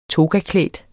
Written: togaklædt

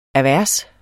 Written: AVERSER

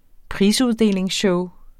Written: prisuddelingsshow

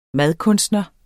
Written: madkunstner

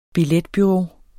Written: billetbureau

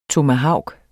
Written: TOMAHAVKEN

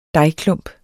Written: dejklump

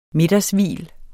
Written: middagshvil

